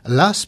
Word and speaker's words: Law: Du XVIIe, on passe au XVIIIe avec John Law, l'as prononcé Voltaire, paraît-il,